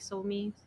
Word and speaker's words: Somi: I mean, lately, since Somi, am I pronouncing her name correctly? Somi? – Yeah.